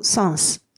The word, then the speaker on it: sens: で、どちらの、あの、サンスですけれども、これ今読んでるように、語末の S が発音されるので注意してください。これ、サンではなくて、サンスです。